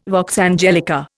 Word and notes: vox angelica: Indian

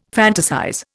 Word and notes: fantasize: Indian

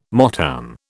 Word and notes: Motown: British English

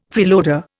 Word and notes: freeloader: Indian

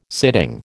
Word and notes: sitting: American English

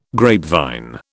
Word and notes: grapevine: British English

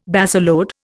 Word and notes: baseload: Indian

